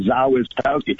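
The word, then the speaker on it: Zawistowski: And yeah, it's actually a simple Polish name because actually all the letters are pronounced. Z-A-W-Zaw-I-S is T-O-W-Tau-S-K-I. Zaw is Towski.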